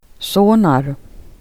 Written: sonade

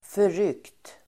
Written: förryckt

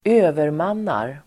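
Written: övermannar

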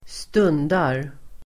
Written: stundar

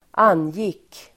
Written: angick

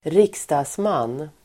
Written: riksdagsman

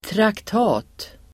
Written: traktat

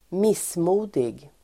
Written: missmodig

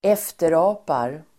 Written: efterapar

